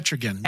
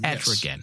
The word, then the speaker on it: Etrigan: Etrigan. – Etrigan.